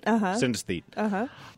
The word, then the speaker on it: synesthete: Reliving this, and I know you're synesthet. Is that how you say it? Synesthete.